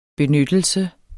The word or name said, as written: BENYTTELSEN